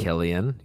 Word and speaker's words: Cillian: How do you say that guy's Cillian or Killian? – I think Murphy. It's Killian. Yeah. Killian.